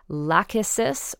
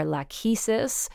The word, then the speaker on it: Lachesis: The Greek goddess Lachesis, or Lachesis, depending on your pronunciation, is the fate who measures out the length of the thread, representing how long someone's life will be.